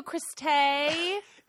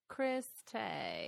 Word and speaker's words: Chris Tay: Well, thank you, Chris Tay. – It's spelled T-H-E. So when I first met him, I saw Chris the, but it was actually Tay. – Chris Tay.